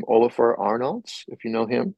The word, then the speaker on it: Ólafur Arnalds: Well, check out Ólafur Arnalds.